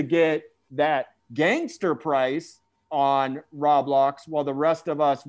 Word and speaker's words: Roblox: And am I not pronouncing that right still? – I believe it's Roblox. – Road with a D? – No, Roblox. – Oh, Roblox.